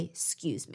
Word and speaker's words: excuse: And so, unless you're pointing out a social no no, you wouldn't want to pronounce the E in excuse me. You just say, excuse me.